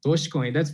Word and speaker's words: Dogecoin: So this one, Dogecoin. How did I do on that one? – That's pretty good. Dogecoin.